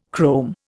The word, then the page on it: chrome: Indian